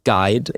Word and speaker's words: GIDH: And they pronounce it as GUIDE initiative.